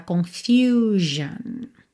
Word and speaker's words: confusion: Também tem que ter atenção aí a essa pronúncia, tá? Confusion. E embora as duas estejam pintadinhas de verde e as duas terminem em ION, atenção que as pronúncias são diferentes, principalmente a letra U. Em solution, nós não temos U, tá? É U mesmo, solution. Já na segunda, confusão, confusion. A letra U tem esse som U, tá?